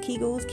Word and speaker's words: Kegels: Was it pronounced Kegels? Kegels, Kegels. K-E-G-E-L-S.